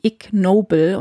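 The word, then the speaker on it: Ig Nobel: Und zwar gibt es neben dem Nobelpreis, der verliehen wird immer, auch den, und ich habe es zuerst als Ig-Nobelpreis gelesen und habe mich genauso wie du gefragt, was könnte denn dieses Ig bedeuten? Und eigentlich wird es ausgesprochen wie Ignoble und das kommt aus dem Englischen und heißt unwürdig.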